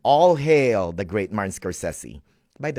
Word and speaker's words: Scorsese: And it is really Scorsese.